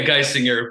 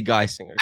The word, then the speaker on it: Geisinger: Yeah, Geisinger. But I don't, mispronunciations are, they happen frequently with this name. So I'm good with whatever, honestly. – Well, it'll be Geisinger.